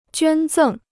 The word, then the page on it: 捐赠: juān zèng